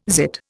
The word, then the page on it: zit: Indian